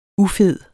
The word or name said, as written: ufed